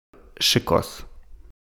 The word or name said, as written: Chicos